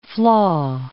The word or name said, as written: flaw